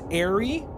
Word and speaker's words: Aerie: This person we're talking to, his name is Gascalian, and their leader's name is Airy. – Airy, yes. – Airy, A-E-R-I-E. – Like if I said, like, Harry Potter.